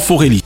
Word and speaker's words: Forelli: Juste avant qu'on commence, j'avais envie de te taquiner sur la prononciation patronymique de ton joli nom, mais je crois que tu le prononces à la française et que tu dis donc surtout Laurent Forelli, même si les origines sont italiennes.